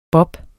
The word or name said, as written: bobs